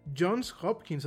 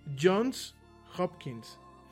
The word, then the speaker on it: Johns Hopkins: No Johnson's, es Johns Hopkins. Johns, eh, no de John, sino de Johns Hopkins. Así lo estaba pronunciando, ¿no? Lo pronuncié mal. Es Jones, Jones, no, no, está bien pronunciado. Jones Hopkins.